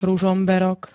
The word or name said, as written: ruzomberok